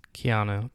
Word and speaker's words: Keanu: Did I say his name wrong? – Keanu. But yeah, Keanu. You just said it fast.